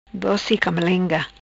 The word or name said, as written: Doci Camalenga